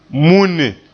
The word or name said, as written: munne